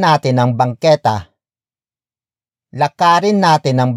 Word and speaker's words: natin: It is followed by natin, natin, natin.